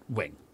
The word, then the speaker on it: Ving: And so actually it is correct that it's V-I-N-G. No, it's not. It's bullshit. It's a mistake. And so you have to know that V-I-N-G, you got to pronounce that wing.